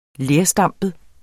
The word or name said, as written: lerstampet